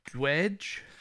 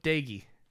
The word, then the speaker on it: Doege: This whole time I was like, Dwedge, Do- Doge. Nope, Dagey.